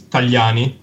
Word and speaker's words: Tagliani: Because Tagliatelle, as you said, is pronounced Tagliatelle, while my surname is Tagliani.